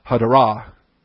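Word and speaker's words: hadarah (הֲדָרָה): The adornment in glory, hadarah, H-A-D-A-R-A. And that first A is superscript. It's really, you rush over that syllable to get to the next two syllables. Hadarah would be how you would pronounce that.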